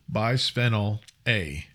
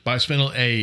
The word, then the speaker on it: Bisphenol A: I know I didn't say that right. Bisphenol? I don't know how to say it. – Bisphenol A. – There you go. – Okay. Bisphenol A is 3 atoms of hydrogen, 1 atom of carbon.